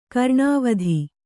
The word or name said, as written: ಕರ್ಣವೇಧ